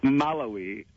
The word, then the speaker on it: Malawi: The emphasis is on the we for me. – Yeah. – Malawi. Mm-hmm.